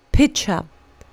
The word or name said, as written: pitcher